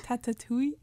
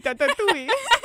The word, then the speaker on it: Tatooine: Tatooine. – Oui, oui, Tatooine.